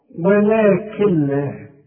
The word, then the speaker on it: ولكن لا: كل نون بعدها لام ما تنطقهاش عند الوصل، اشبك الكاف مع اللام، شوف، ولكن لا، وكأن النون غير موجوده.